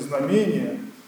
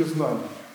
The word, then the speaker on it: знамение: Часто даже ударение в этом слове произносят неправильно. В календаре там специально не знамение, а вот все-таки знамение.